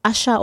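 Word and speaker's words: Aṣa: I've been listening to Asa as well. Asha. Sometimes it's pronounced Asha or Asa from Nigeria.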